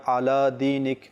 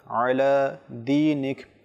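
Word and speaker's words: আয়লা দিনিক (على دينك): তো কেউ যদি পড়ে আলা দিনিক তাহলে ভুল হবে, বরং আয়লা আয়লা দিনিক।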